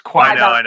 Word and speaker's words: Qui-Gon: It's Qui-Gon.